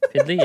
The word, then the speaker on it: PDLY: P-D-L-Y. Pidley? – Pidley, yeah.